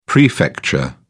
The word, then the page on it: prefecture: British English